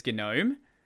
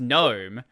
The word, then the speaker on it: GNOME: I've noticed that a lot more of the newer people that come to Linux base have no idea that GNOME is GNOME. Like, people are like, why are you saying it as GNOME? Yeah. It's pronounced GNOME.